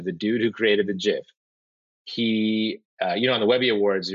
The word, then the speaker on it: GIF: And his acceptance speech was just, it's pronounced, and then J-I-F. It's pronounced GIF.